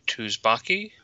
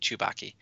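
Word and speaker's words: Tsubaki: And so Henry Gold did the voice of the Jedi Tuzbaki. – It's Sue. It's like Sue. It's kind of like it's pronounced as Z. The T-S is like a Z sound.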